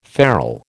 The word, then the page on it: farl: American English